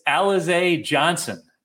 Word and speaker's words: Alize Johnson: It's kind of like a bucket list thing, but I did look up the pronunciation. It's Alize Johnson at 3,500 on FanDuel.